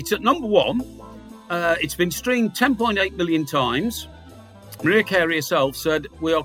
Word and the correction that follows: Mariah: She's Mariah, right. By the way. We ought to pronounce her name properly. – All right. – Yeah. – All right. Thank you, Miss Jones. Yeah. Thank you. – That's all right. – In an English pronunciation, Mariah Carey.